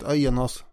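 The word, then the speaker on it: Aeneas: Men den mycket kändare grundarmyten handlar ju om Aenas. Ska vi uttala det så? – Ja, jag har kommit på något bättre sätt. Aenas.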